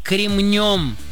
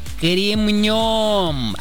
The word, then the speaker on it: кремнем: Ударение в нем всегда ставится только на последний слог, поэтому правильно говорить «кремнем», «кремнем».